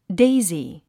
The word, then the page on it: daisy: déizi; デイズィ